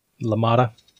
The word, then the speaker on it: LaMotta: LaMotta.